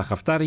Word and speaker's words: Χαφτάρ: Ο κύριος Χαφτάρ, ο Μπελκασέμα, το προφέρουμε σωστά, Χαφτάρ, είχε συνομιλίες με τον Πρωθυπουργό, τον Υπουργό Εξωτερικών και τον Υφυπουργό Εξωτερικών, Κατερίνα.